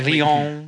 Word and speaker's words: Lyon: Your drunk little escapade you had in Lyon. – We sat and met, and it's like, okay, what? – Nice pronunciation, Lyon. Yeah. Lyon.